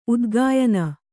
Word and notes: ಉದಗಯನ: udagayana

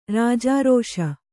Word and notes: ರಾಜಾರೋಷ: kannada

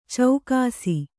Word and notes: ಚೌಕಾಸಿ: caukāsi

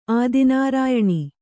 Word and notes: ಆದಿನಾರಾಯಣಿ: Kannada

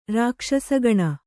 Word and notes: ರಾಕ್ಷಸಗಣ: rākṣasa gaṇa

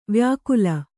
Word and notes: ವಾಯ್ಕಾಲು: Kannada

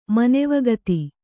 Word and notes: ಮನೆವಗತಿ: Kannada

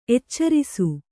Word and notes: ಎಚ್ಚರಿಸು: kannada

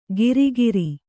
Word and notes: ಗಿರಿಗಿರಿ: giri giri

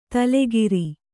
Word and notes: ತೈಲಕಾರ: tailakāra

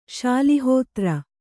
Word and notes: ಶಾಲಿಹೋತ್ರ: śali hōtra

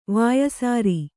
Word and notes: ವಾಯಸಾರಿ: Kannada

